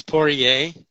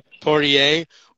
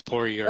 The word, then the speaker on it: Poirier: It's Poirier, so it's French. Poirier, or English is Poirier.